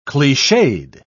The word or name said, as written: cliched